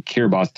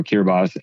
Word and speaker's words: Kiribati: It looks like Kiribati on the map, but T-I is pronounced with an S in their country. So Kiribati, Tarawa, Kiribati, and about 3 days away, sail to Howland Island.